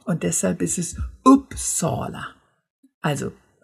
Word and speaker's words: Uppsala: Im Schwedischen ist es ein etwas offenes U. Wenn ich meinen Anfängern, in den Anfängerkursen, die schwedische Aussprache näher bringe, dann sage ich ihnen immer, stellt euch mal vor, euch haut jemand so richtig in den Solarplexus rein. Und der Laut, den ihr dann ausstößt, das ist das U. Ne? Und deshalb ist es Uppsala. Die Betonung ist tatsächlich erstmal auf der ersten Silbe, eben auf diesem Upp, aber es ist kein Uppsala,